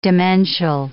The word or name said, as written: demential